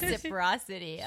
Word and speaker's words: reciprocity: How do you say that word? – Reciprocity. Oh, okay.